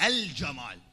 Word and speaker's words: الجمال: هي صحيح الصحيح؟ لا، لأننا قمري، فلازم شو تقول؟ الجمال، الجمال، الجنة. لازم تلفظ شنو؟ تلفظ اللام.